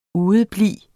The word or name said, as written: udeblive